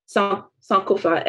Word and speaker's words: Sankofa: Sankofa, S-A-N-K-O-F-A.